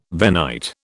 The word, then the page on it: Venite: British English